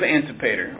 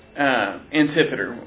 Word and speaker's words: Antipater: It begins with Antipater, or Antipater. I've heard it pronounced both ways. I'll probably use Antipater.